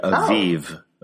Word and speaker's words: Aviv: I don't know why these get changed to B's instead of V's. In Hebrew, it would be Aviv, which is actually the word for spring.